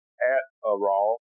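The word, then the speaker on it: At-A-Raw (עטרה): And it's pronounced At-A-Raw, which simply means crown.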